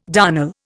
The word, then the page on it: darnel: Indian